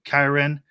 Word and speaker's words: Kyren: Like that first week with Kyren. Yeah, did I say his name right this time? – You got it.